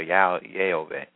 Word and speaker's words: Yahweh: I'm telling you what his, to the best of my ability, from what we know, what his true name is and the right pronunciation is Yahweh or Yehovah.